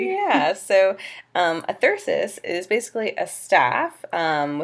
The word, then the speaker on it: thyrsus: Dionysus is Thyrsus. Thyrsus. Yes. – Okay. So it's T H Y R S U S.